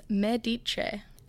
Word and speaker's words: Medici: How on earth do we even pronounce this? – Given my Teesside tones, I don't think I'm your go-to girl on pronunciation. But I recently caught up with the cast, who informed me it's Medice.